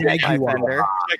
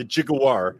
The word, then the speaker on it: Jaguar: Jaguar. Yeah, you have to say it like they do about the car, the Jaguar.